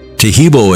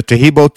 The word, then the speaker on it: Taheebo: Taheebo is spelled T-A-H-E-E-B, like boy, O, then the word tea, and then the word club.